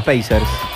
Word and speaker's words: Pacers: Así se pronuncia. Pacers.